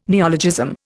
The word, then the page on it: neologism: Indian